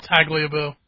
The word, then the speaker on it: Tagliabue: It's actually Paul Tagliabue. – See? – Really? – It's an extra syllable.